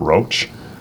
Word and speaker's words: Roch: Isn't it Roach? – Well, is it pronounced Roach? R-O-C-H. – Yeah, yeah. – R-O-C-H.